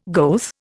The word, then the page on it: goeth: Indian